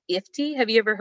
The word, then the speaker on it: IFTTT: So IFTY, it's spelled IFTTT.